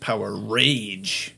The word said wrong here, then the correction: Powerade: Power Aid.